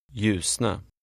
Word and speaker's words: ljusna: Remember the sound of the initial LJ. – Ljusna.